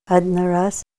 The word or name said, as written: adna ras